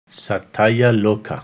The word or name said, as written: Satayaloka